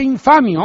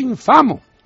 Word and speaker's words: infamo: No se dice infamio, hombre, se dice infamo.